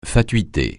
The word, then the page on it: fatuité: francais